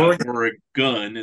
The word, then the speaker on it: Oregon: Everybody outside of Oregon, it's like a gun.